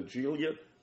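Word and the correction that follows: Giulia: Now seeing that name and hearing other people from other wrestling podcast say the name Julia. I think that's what that is.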